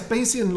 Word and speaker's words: Bayesian: Am I pronouncing that right? – Yes, Bayesian logic is, well, it's a statistical technique.